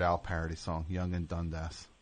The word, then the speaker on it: Dundas: Dundas is how you say it.